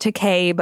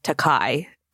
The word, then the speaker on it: Takei: It is pronounced Takei, but Bill has always said Takai.